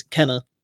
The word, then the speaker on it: Kena: I was like, I'm going to Google how to pronounce it because otherwise I'll be saying it's Kena the whole time. It is Kena.